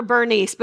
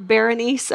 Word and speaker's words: Berenice: Ptolemy decides he is going to give his daughter, I call her Bernice, but it's not really spelled like that. It's like Berenice, okay? But I'm going to say Bernice, okay?